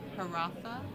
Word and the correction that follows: Hiawatha: Hiawatha. – I can't pronounce that one.